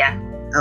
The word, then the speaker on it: philia: Philia.